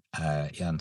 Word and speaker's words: Iain: My first name is spelled I-A-I-N. Kind of looks like Lane sometimes to some people.